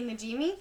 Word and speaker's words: Najimy: All these nuns that we've referenced so far, Mary Robert, Mary Patrick, Mary Patrick is played by Kristen Ninimajima. – Kathy Najimy. – Thank you. I never say her name right.